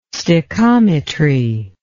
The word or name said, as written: stichometry